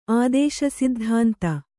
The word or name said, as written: ಆದೇಶಸಿದ್ಧಾಂತ